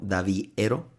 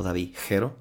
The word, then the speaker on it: Davihero: Su arroba es Davi, así D-A-V-I-H-E-R-O, Daviero o David Jero, si es que pronuncian la H.